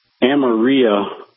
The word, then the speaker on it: Amarillo: I'm not familiar with Amarillo or Amarillo. Is that anywhere near Amarillo? – Yeah, well, it's the same place. – Same place. I'm pronouncing it with a Spanish pronunciation.